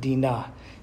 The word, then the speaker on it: Dinah: Afterward, she gave birth to a daughter and named her Dinah, or pronounced Dinah in Hebrew.